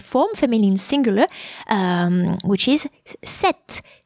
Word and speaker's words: cet: the demonstrative adjective would be C-E-T, pronounced set.